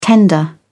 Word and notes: tender: British English

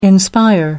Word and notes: inspire: American English

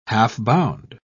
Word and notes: half-bound: British English

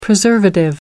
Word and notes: preservative: American English

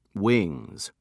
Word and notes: wings: British English

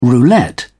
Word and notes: roulette: British English

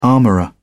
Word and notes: armorer: British English

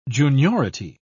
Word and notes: juniority: British English

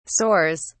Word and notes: soars: British English

